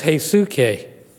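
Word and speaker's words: heisuke (ἡσυχία): Okay, this one is heisuke. Heisuke.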